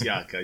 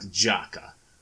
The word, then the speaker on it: Jakob: I mean, my name alone is basically the point of that. It's Yaka, it's not Jaka.